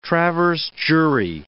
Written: traverse jury

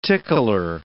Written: tickler

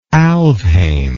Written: Alfheim

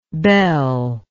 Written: bell-like

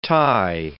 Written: Thai

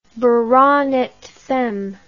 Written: baronet femme

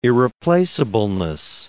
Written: irreplaceableness

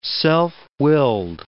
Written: self-willed

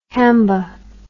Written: hamba

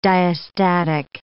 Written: diastatic

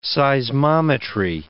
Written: seismometry

